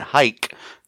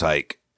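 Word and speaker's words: Huyck: Hike. You're welcome, guys. I pronounced it right.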